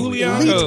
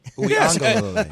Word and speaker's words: Uiagalelei: So you are in Uiagalelei. – Elite company. – Uiagalelei.